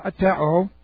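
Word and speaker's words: aiteō: If you would open a lexicon and you would look up the word atero, it's like this. A-I-T-E long O. It's a request from an inferior person to a superior.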